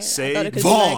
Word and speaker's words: SaVon: So is there a difference in pronunciation with that big V? – No, say Vaughn. Yeah. That's why the V is emphasized.